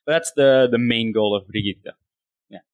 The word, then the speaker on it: Brigitte: If you listen to the way the voice actor, that's the correct pronunciation. So it's Brigitte. – Brigitte.